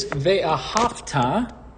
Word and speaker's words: Ve'ahavta: This is Ve'ahavta on page 154. Notice that the melodic movement will be on the ha syllable, not on a.